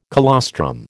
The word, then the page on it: colostrum: American English